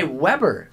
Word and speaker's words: Weber: Or should I say Weber State? – That would be the correct way to say it.